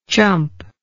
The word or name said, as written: chumpness